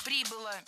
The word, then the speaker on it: прибыла: Когда совершенно безумно ее мама прибыла в Голливуд, зачем-то где-то устроилась какой-то монтажницей, вышла, была замужем, родила дочку. – Прибыла, неверно, правильно, прибыла. Ударение фиксируем все там же, на окончании.